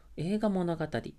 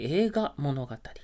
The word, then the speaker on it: 栄花物語: いて、えー、書かれたものっていうものが、まあ、映画物語っていうものがあるんですね。こちらも歴史物語なんですけれども映画物語、は「栄える花と書きます。あのお花の花ですね。栄える花と書いて映画物語。映画と濁りますね。